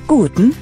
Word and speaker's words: Guten: The word for good is spelled G-U-T-E-N. It's like gluten without an L. The word for morning is spelled M-O-R-G-E-N, almost like the woman's name.